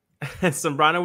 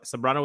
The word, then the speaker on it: Sambrano: Sambrano with an S, not like Carlos Zambrano. So Sambrano with an S and then U L I S E S.